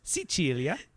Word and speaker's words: Sicilia: We're going to Sicily, which in Italian, it's pronounced Sicilia.